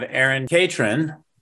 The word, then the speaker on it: Erin Catron: So I have Erin Catron.